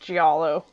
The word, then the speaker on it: giallo: It's pronounced giallo.